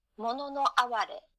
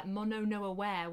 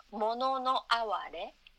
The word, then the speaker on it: mono no aware: yeah, I was really anglicizing it and calling it uh, mono no aware, which is uh, very very British. yes, yes, it's mono no aware and it sounds much more beautiful uh, in your language.